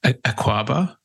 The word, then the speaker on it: Akwaba: Akwaba? – Yes, correct. You got it. – Beautiful. I thought that the two A's that might be, that little swing of it.